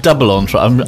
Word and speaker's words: double entendre: Double entendre. I'm sure it's French. It's good French. – I like double entendre. I like saying double entendre. – Good French pronunciation.